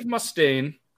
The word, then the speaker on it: Mustaine: Before forming Megadeth, Dave Mustaine, sorry, that's how you say it, right? Yep. He was a key Mustaine.